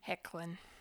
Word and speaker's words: Hoechlin: No, I never knew how to pronounce his name until recently. But yeah, it's Tyler Hecklin. – Hecklin. Okay. – Yeah, it's a weird spelling.